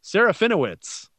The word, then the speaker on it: Serafinowicz: Serafinowicz. – Tell us more. – Oh, okay then. Peter Serafinowicz.